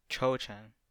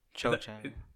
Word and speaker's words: Cho Chang: Rowling coming up with an Asian name, Chu Chang. Cho Chang. Is it Chang? Oh no. Cho Chang.